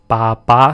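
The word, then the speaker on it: ba ba (八八): So BB is used in instant messaging, it's shorthand, it means baby, and then 88 in Mandarin is pronounced ba ba, so which is used as a shorthand in China or any place that speaks Mandarin to mean bye-bye.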